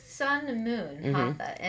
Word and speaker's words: Hatha: But I saw stuff like Hatha. Is that how you say it? – Yes. – Okay. – Exactly. – Oh, yeah, I said it right.